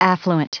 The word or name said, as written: affluent